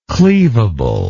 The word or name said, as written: cleavability